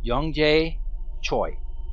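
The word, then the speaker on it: Yongjae Choi: I'm going to spell that in case anyone gets offended. It's YONGJAE. Last name is spelled CHOI. Young Jay Choi.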